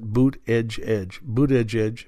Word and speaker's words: Buttigieg: Yahoo News, but also in the San Francisco Chronicle, Mayor Pete Buttigieg, that's how you pronounce that, Buttigieg,